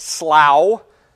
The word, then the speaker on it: slough: I'm going to pronounce it slough, like plow or cow.